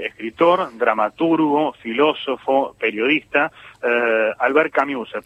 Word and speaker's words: Camus: Camus. – Pensá en una I y decí una U. O pensá en una U y decí una I y ahí te sale.